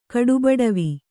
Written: ಕಡುಬಡವಿ

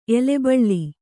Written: ಎಲೆಬಳ್ಳಿ

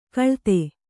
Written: ಖಳತಿ

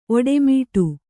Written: ಓಡುಮಾಡು

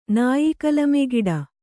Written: ನಾಯಿಕಲಮೆಗಿಡ

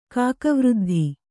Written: ಕಾಕವೃದ್ಧಿ

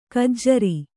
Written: ಖಜ್ಜೂರಿ

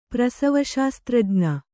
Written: ಪ್ರಸವಶಾಸ್ತ್ರಜ್ಞ

